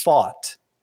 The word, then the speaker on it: Pfaadt: not the best use of Pfaadt's name, which by the way, I think we're pronouncing it wrong slightly. It's fought. – I think you're right fought. about that. It kind of sounds like the word thought.